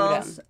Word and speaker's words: L'Agence: Hur uttalas det då? – Agenturen? L'Agence. Ja, Den heter mm. bara L'Agence mm.